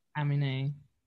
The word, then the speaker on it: Aminé: She sang another song that I recommended previously, but this one sounds really different and it features Aminé. That's how you say his name? Aminé. – Aminé.